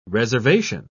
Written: reservation

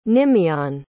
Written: nimiane

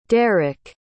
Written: deryck

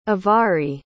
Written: avari

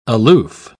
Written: aloof

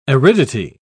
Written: aridity

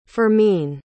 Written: fermin

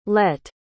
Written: lett

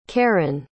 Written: karren

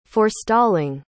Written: forestalling

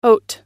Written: haute

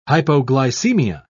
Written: hypoglycemia

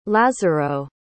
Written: lazaro

